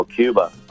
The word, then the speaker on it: Q-Bar: Q bar, ¿verdad? Q-bar. Cuando se pronuncia el Q bar. Entonces cuando lo pronuncias como Cuba, porque mucha gente no pronuncia la R al final, ¿no? En inglés.